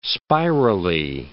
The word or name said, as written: spirally